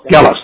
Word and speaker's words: Gellis: Is it pronounced Jealous? Jealous? Jealous. Hard G.